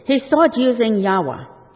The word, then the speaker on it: Yahweh: The pronunciation is Yahweh.